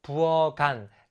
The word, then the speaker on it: 부엌 안: 그리고 뒤에 붙여 읽어주면 부엌 안, 간이 되는 거예요. 칸이 아니라.